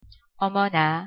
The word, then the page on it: 어머나: Korean